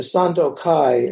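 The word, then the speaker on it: Sandokai: And he's much better known for writing the Sando Kai, as it's pronounced in Japanese, the harmony of difference and sameness, which maybe you all chant occasionally.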